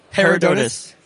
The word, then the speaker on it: Herodotus: Herodotus.